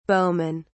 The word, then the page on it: bowman: British English